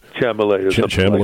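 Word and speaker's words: Chalamet: I've just learned how to say his name. Chamolet, I think it is. – Chamolet. Like that.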